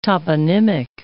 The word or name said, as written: toponymic